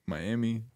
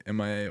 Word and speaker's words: MIA: I don't really know if you're supposed to pronounce it like Miami, like MIA, or if it's like Mia, you know? I don't know. – Wasn't it Mia? Right, copyrighted. – Mia. Yeah, so it's not Miami. That's how you pronounce it.